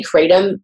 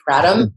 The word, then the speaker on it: kratom: So it's spelled Kratom. I say Kratom. A lot of people say Kratom. I don't know.